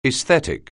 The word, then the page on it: aesthetic: anglais